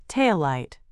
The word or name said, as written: taillight